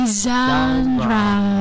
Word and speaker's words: genre: Jadi baca yang benar adalah tadi genre.